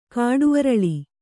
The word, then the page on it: ಕಾಡುಅರಳಿ: kāḍu araḷi